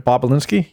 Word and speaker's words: Bobulinski: So here's a video from 3 years ago where Tony Bobulinski, I think I said that right, Bobulinski, yeah, says he has evidence against Joe Biden and Hunter Biden.